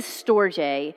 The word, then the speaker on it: storge: But what is storge? It's spelled S-T-O-R-G-E, but it's pronounced S like store and then J.